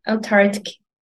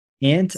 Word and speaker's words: Antarctica: This word has a silent letter. – Oh, Antarctica. – Antarctica. – So I don't say the C. – This, the first C.